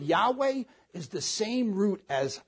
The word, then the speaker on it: Yahweh: So there's very close relationship when God says I am has sent you, and the Tetragrammaton pronounced Yahweh.